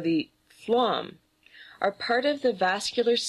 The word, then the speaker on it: phloem: Banana strings are actually officially called, in scientific terms, phloem, and that's spelled P-H-L-O-E-M. So it sort of reads like phloem, but pronounced phloem.